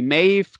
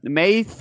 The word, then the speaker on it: Maeve: Zum Beispiel Maeve kannst du äh, Maeve aussprechen oder Maeve oder Math. Also es gibt ganz viele verschiedene Arten, es auszusprechen.